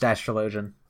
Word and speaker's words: Astrologian: Oh, no, I mean, like, it's pronounced Astrologian.